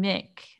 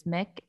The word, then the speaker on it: Muick: So there's two remaining, Sandy and Mick, I believe is how it's pronounced. It's spelled Muick, but it's Mick.